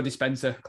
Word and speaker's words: Dispenza: I know you've sort of been reading the Jody uh, Dispen, what is it? I've mispronounced that, didn't it? The Jody Joe Dispenza collections.